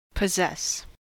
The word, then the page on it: possess: American English